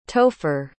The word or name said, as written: topher